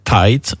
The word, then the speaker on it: Tait: Qui s'appelle Audrey Tate. Ça s'écrit T-A-I-T. On peut aussi dire Tight.